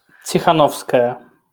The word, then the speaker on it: Tikhanovskaya: Svetlana Tikhanovskaya.